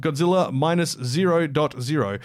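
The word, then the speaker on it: Godzilla Minus Zero: So, yeah, the official title is apparently pronounced Godzilla Minus 0.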